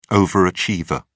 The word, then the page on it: overachiever: British English